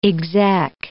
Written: exec

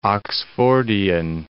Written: Oxfordian

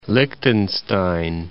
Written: Liechtenstein

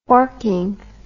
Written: forking

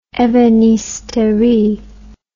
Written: ebenisterie

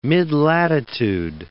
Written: midlatitude

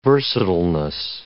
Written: versatileness

